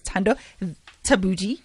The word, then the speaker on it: Thando Thabethe: I think the way of pronouncing it is Thando Thabethe.